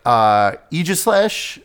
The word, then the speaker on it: Aegislash: Aegislash.